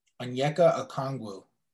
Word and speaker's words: Onyeka Okongwu: How do you pronounce the USC senator's name? – Onyeka Okongwu. – Onyeka Okongwu. Okay.